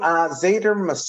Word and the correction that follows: Zafar: Massoud, is that how you pronounce his name? – Zafar Massoud. You're forgiven. It's a Pakistani name.